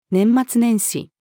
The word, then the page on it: 年末年始: female